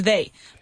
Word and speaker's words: they: But what I need you to make sure you pronounce correctly is they. Because otherwise it sounds like there. And there is haber. So we have to like pronounce it correctly with the Y.